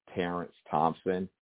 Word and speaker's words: Terence Thompson: My social media platform that you can engage with me mostly would be on Facebook at Producer Terence Thompson. And my name is spelled T-E-R-E-N-C-E Thompson, T-H-O-M-P-S-O-N.